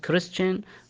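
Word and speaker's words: Christian: ومثل ما تفضلنا حرف السي والاتش ينطق حرف الكاف في حالات شاذة، مثل كلمة كيمستري معناها كيمياء، وعندنا كلمة كريستيان معناها اللي هو نصراني أو مسيحي، أو كلمة كرايست نفس كلمة كريستيان، كرايست معناها طبعا المسيح.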